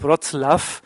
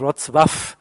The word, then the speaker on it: Wrocław: Das heißt, ist zu einem Überfluss auch noch falsch auszusprechen, weil es im korrekten Polnisch eben nun mal Wrocław heißt. Es ist ja dieses durchgestrichene L, was anders ausgesprochen wird.